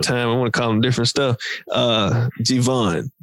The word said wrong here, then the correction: Giveon: G-I-V-E-O-N. – Giveon. – Giveon. Okay. My bad.